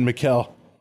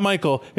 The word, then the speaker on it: Michel: Mikkel. It's not Michael. He has to pronounce it M-I-C-H-E-L. Like, literally makes a point to it.